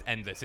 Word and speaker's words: Endlesss: Well, that's why I keep saying Endless. Is that how you say it, or is that just me? – No, I do. When we're talking about it with the team, it's just Endless.